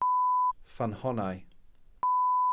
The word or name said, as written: ffynhonnau